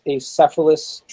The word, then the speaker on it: acephalous: I think the term is acephalous tribes in the region that we're going to? I think I'm saying that right. – Did you say acephalous? – Acephalous, yeah.